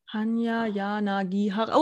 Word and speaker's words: Hanya Yanagihara: Hanya Yanagihara.